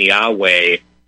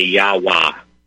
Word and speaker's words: Yahweh: The H, everyone seems to be in agreement. All these different sources seem to be in agreement that that's pronounced as A-H. In other words, ah. – Right. – And so right off the bat, verifying that, me saying Yahweh is already wrong. because both H's should be ah.